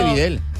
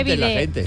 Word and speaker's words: bidé: Que no se dice Videl. – Que no se dice Videl, se dice bidé.